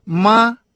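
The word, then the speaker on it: ма (妈): Например, слово «ма» – первый тон – означает «мама», а «ма» – второй тон – «конопля». «Ма» – третий тон – имеет значение «лошадь», а «ма» – четвертый тон – это глагол «ругать».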